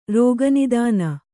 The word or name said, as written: ರೋಗನಿದಾನ